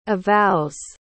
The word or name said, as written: avows